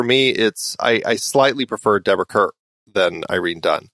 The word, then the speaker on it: Kerr: And it is Deborah Kerr. I said it right.